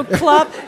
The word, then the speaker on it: klop: Okay, so klop, it's a hit. – Klop, no, klop. Klop, yeah, okay.